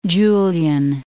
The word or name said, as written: Julian Alps